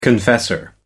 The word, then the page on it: confessor: American English